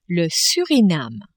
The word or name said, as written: Surinam